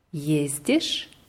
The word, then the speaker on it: ездишь: Я езжу, ты ездишь, он, она ездит, мы ездим, вы ездите, они ездят. Und ist dir aufgefallen, wie das Verb immer betont wird? – Auf der ersten Silbe.